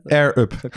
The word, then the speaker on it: Air Up: Air Up. Je moet de R moet je goed doen.